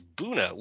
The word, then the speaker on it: Buena: And they're like, it's Buna, which is exactly what you said. And I'm like, are you like B-U-E-N-A is a Spanish and you pronounce it buena.